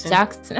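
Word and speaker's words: Jaxn: Wait, do you know that the first time I saw his name, so his last name is spelled J-A-X-N, I was so confused. I'm like, is it Jan? Like, is the X silent? But then I saw a YouTube video and it's like Jackson.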